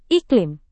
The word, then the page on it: iklim: Indonesian